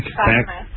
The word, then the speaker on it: Clackamas: Clackamas. – What's that? – Clackamas. – Crackamas. Right. – How do you spell that? By the way, is that two? – It's Clackamas.